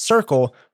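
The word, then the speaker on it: CRCQL: But since the acronym looks like it's pronounced Circle, that's what they went by.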